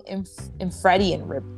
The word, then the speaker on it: infradian: On the flip, on the flip side, we've got, and correct me if I'm saying this wrong, infradian, I-N-F-R-A-D-I-A-N, infradian rhythm.